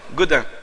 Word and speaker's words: Godin: Godin. G-O-D-I-N. Mais prononcé en anglais, Godin.